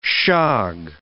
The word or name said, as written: shog